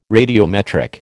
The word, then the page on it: radiometric: American English